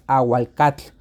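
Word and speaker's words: āhuacatl: El náhuatl eh, es aguacatl, ¿no? En la pronunciación náhuatl.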